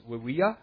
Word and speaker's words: Vivia: Her name would probably actually be pronounced Wivia, because V's are pronounced like W's, but that sounds silly to us, so we don't usually say that.